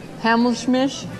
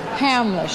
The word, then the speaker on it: Hamlisch: And Marvin Hamsmish for who? – The way we were. – Who? – Hamlisch. Hamlisch.